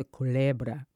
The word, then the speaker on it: Culebra: Can you say it? How do you say it? – The Culebra. – The Culebra River.